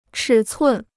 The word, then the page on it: 尺寸: chǐ cun